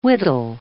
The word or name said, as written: wittol